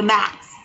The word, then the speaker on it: The Maxx: Did you say the mask? – The Maxx. Spell it. – Maxx. M A X X.